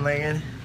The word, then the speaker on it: Langan: Guys, it's here with Kevin Langan.